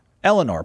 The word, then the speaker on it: Eleanor: Eleanor probably is the way to pronounce that with a little Boston in it.